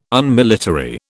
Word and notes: Unmilitary: British English

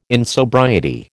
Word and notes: insobriety: American English

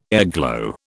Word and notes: airglow: British English

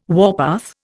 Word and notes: warpath: Indian

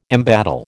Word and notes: embattle: American English

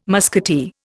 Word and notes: musketeer: Indian

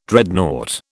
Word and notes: Dreadnought: British English

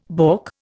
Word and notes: balk: Indian

